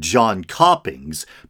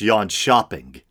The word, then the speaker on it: Jönköpings: It looks like John Coppings, but I believe it's Yon Shopping.